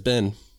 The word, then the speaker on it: Ben: And that's why, because I say it like a southerner, mm-hmm. Ben, mm-hmm. but like it has an I in it. And when I was up north, they didn't like it.